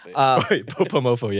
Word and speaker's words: Bopomofo: I went to go support your spot, Boba Mofo Cafe. – Yeah. – I went to the one in Artesia, yeah. Cerritos. – Boba Mofo, yeah.